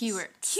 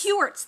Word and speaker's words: Heuertz: I hope I say her name right, Phileena Hertz. Hertz. – Heuertz. – Heuertz. Thank mm-hmm.